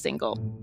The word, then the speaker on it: Singal: That's Singel spelled like Singel, S-I-N-G-A-L, but pronounced single.